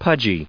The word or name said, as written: pudgy